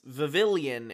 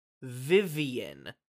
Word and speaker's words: Vivillon: We will keep pronouncing it as Vivillon in this episode, even though that is not correct. It seems as though the name of this Pokemon is pronounced Vivian. The L's are silent.